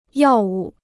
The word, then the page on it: 药物: yào wù